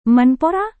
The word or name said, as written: menpora